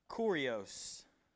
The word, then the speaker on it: kurios: That's the word kurios. That's how I pronounce it. You can pronounce it however you want to. Kurios, K U R I O S. K U R I O S. It also, it also really looks like curious.